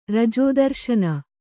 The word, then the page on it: ರಜೋದರ್ಶನ: kannada